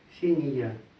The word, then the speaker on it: Ксения: На конце пишется всегда «а», а читается как «я». Ксения. Так же, как, например, Мария. Читается Мария.